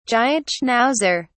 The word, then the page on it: giant schnauzer: British English